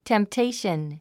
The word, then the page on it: temptation: tem p téiʃən; テンプテイション